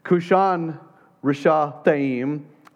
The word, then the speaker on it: Cushan-Rishathaim: It rhymes. Kushan Rishathaim, Aram, Naharaim.